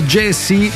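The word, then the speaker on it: Gyasi: Si pronuncia Jesse, si scrive G-Y-A-S-I e questo è il 33 giri datato 2025 dal titolo Here Comes the Good Part.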